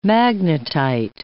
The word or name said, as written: magnetite